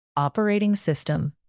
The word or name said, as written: operating system